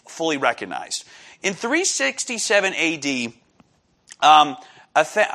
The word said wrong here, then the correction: Athanasius: oh man, I can never pronounce his name properly. Athanasius of Alexandria, Augustine, and Jerome all acknowledged in letters to churches the 27 books of the New Testament.